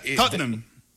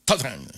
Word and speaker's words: Tottenham: Enséñanos, porque es que esa Tottenham. Tottenham. Son dos sílabas.